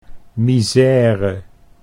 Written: misère

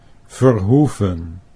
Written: Verhoeven

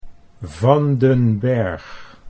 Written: Van den Berg

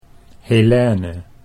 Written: Helene

